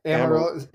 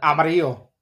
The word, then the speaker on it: Amarillo: Amarillo? – Yeah. Amarillo. Amarillo. – Yeah. – Yeah, Amarillo. – Amarillo. Okay. The Amarillo Giants. – If you want to go Hispanic with it, Amarillo. – Amarillo.